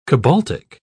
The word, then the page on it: cobaltic: British English